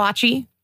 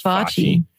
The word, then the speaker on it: foci: How do you say that word? The notion that history foreshadows the future. – It's Focci. – Focci, of course. – Yes, it's Focci.